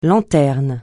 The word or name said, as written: lanterne